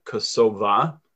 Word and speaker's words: Kosova: The Albanians pronounce it Kosova. So sometimes I might say Kosova and sometimes I might say Kosovo. But that's why Kosova is the Albanian kind of pronunciation.